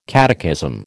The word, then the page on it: catechism: American English